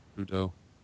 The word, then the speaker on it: Trudeau: Was Justin Theroux? Is that how you say it? – Trudeau.